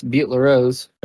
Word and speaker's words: Butte La Rose: That's, yeah, that's Butte La Rose.